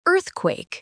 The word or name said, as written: earthquake